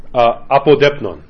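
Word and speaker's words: apodeipnon: Apodepnon. Apodepnon. Apodepnon, really, if you're going to say it right.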